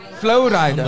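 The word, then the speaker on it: Flo Rida: Florida was there. Florida, the guy. Is that how you say it? Flowrider.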